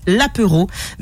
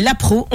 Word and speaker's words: apéro: Alors, et je tiens à préciser que le panel vous a accordé cette bonne réponse, même si ça se prononce l'apéro, mais l'apro, on a pris, d'accord ? – D'accord.